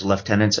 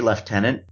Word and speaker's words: lieutenant: And I do say lieutenant because I'm Canadian, and the entire Commonwealth pronounces it that way. There are other ways to pronounce that word. However, I choose to say lieutenant.